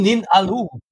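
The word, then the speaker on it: Im Nin'alu: Auf Platz 13, I'm Ninuala von Ofra Haza. Wie wird das ausgesprochen? – Eben Nin Alu von Ofra Haza.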